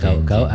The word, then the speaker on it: gaok: Nanti bacanya gawedak gawedak. – Gawedak gawedak. – Itu kan O-nya O suara. – Oh, ngoten. – Itu kalau tidak paham seperti itu. Mana gagak gaok-gaok harusnya, tapi dibaca gawedak-gawedak.